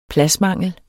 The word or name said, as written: pladsmangel